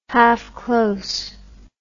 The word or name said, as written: half close